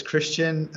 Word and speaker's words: Kristian: It's Kristian and then the word Ultra.